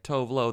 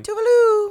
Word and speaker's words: Tove Lo: It's not news, to quote Tuvalu, that her name is Tuvalu and not Tovlo. We speak English in America, so when we see T-O-V-E-L-O, our impulse is to say Tovlo phonetically. But in Sweden, you say Tuvalu.